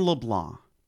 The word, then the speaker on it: LeBlanc: Say your name just like Jordon LeBlanc. – LeBlanc. See, you didn't say a C. That's the problem.